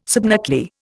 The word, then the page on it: subnuclear: Indian